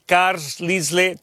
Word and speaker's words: Carlisle: Se escribe Carlisle, así como lo escuchas en español, C-A-R-L-I-S-L-E, Carlisle, Carlisle, Caterpillar, ya la conoces, y bueno, General Dynamics.